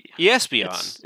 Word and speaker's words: Espeon: Espeon is how it should actually be pronounced.